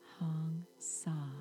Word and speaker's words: Hong Sa: It's pronounced Hong, just like Hong Kong, H-O-N-G, and Sa, just like the English word saw, S-A-W.